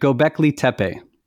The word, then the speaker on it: Göbekli Tepe: Göbekli Tepe. Is that how you say it? – Right. Göbekli Tepe.